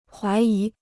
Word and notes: 怀疑: huái yí

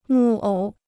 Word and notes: 木偶: mù ǒu

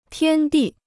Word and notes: 天地: tiān dì

